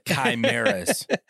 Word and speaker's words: chimeras: Like, you know, we had an episode on Shadow Band Syndicates where we're talking about Crimeas, you know what I mean? Crimeas. Not, I was saying it wrong on the episode. I forgot what I was saying, but I'm not going to say it again because I'll lose it. – Chimeras. – But these, yeah, chimeras.